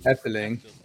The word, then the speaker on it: Efteling: Efteling.